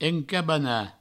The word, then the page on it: encabanar: encabana